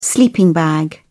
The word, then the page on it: sleeping bag: British English